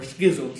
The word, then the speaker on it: schism: Or schism sometimes, but schism is often how it's pronounced.